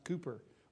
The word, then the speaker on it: Cowper: It's spelled C O W P E R, spelled like Cowper, but it's pronounced Cooper, William Cooper, uh, back in the 18th century and uh, 19th century.